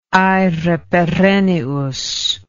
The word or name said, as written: aere perennius